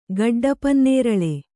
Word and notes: ಗುಡ್ಡಪನ್ನೇರಲು: guḍḍa pannēralu

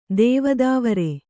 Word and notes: ದೇವದಾವರೆ: Kannada

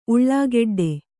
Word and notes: ಉಳ್ಳಾಗೆಡ್ಡೆ: kannada

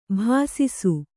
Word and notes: ಬೆಸಸು: besasu

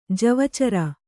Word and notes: ಜವಚರ: Kannada

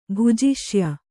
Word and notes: ಭುಜಿಷ್ಯೆ: bhujiṣye

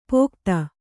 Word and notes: ಪೋಕ್ತ: pōkta; dial.

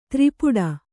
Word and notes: ತ್ರಿಪುಟ: tri puṭa

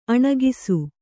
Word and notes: ಅಣಕಿಸು: aṇakisu

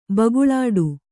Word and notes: ಭುಗಿಲಿಡಿ: bhugiliḍi

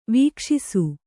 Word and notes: ವಿಕಾಶಿಸು: vikāśisu

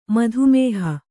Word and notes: ಮಾತಾಮಹಿ: mātāmahi

